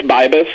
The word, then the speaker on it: Bibus: So, Scott, should we call you Scott or Beebus or Bibus? How do you say your last name? – It's Bibus.